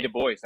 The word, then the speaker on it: Du Bois: And don't you dare say Du Bois, Pablo. It's not pronounced Du Bois. I know you seem like the type that would try to put that French spin on it.